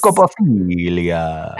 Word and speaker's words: Scopophilia: Thank Scopophilia. You. Am I saying it right? – Yes. – Scopophilia.